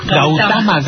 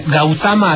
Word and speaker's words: Gautama: Gautama, Gautama, así es como se pronuncia.